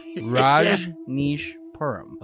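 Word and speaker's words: Rajneeshpuram: Well, first of all, how do we pronounce it? – Rajneesh Puram.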